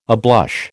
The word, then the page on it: ablush: American English